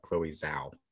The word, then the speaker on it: Chloe Zhao: It's Chloe Zhao, I believe is how you pronounce the name of the woman who directed No Man Land, who did The Eternals after that.